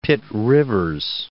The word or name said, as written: Pitt-Rivers